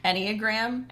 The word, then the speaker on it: Enneagram: So we discovered this personality trait quiz called an anagram. – Enneagram? – Enneagram. – Enneagram. – It probably is, like, the easiest way to say it is probably the right way to say it. So anagram. Enneagram? – Enneagram. E-N-N-E-A-G-R-A-M. – Yes. Perfect. So what it does is group together,